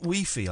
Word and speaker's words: Wi-Fi: So we're supposed to be pronouncing it Wi-Fi. I've been told, but then in France, do they pronounce no, hi-fi? Wi- Wi-Fi. Wi-Fi. Yeah, like hi-fi.